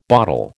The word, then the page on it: bottle: American English